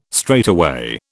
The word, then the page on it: straightaway: British English